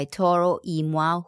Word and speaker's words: Toro y Moi: BFM 89.9, and that was Still Sound by Toro y Moi, whose name I butchered just 2-3 minutes ago.